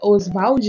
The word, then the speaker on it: Oswald: Oswald, né? de Andrade, que é a sílaba é no Vald.